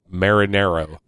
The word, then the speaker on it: Marinero: Jason Marina. – Marinero. Like, say it like Marinara.